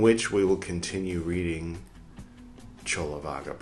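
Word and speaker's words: Cullavagga: Pay no attention to how I pronounced it, Kulavaga, for the first long time.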